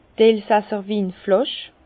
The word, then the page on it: d' elsasser Winflàsch: Alsatian; Bas-Rhin; Herrlisheim